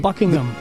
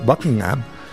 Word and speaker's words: Buckingham: Es Buckingham. – Eso y de hecho, inglés perfecto, el H medio que se es medio silencio. Buckingham. – Más House. – O menos. – No se pronuncia mucho.